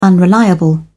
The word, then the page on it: unreliable: British English